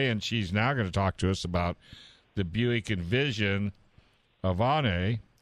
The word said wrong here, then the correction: Avenir: Or event, how do you say that? – I think it's Avenir. It's A-V-E-N-I-R. Okay. So I'm going to go with Avenir.